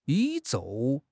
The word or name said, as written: 宇宙